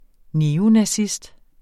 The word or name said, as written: neonazist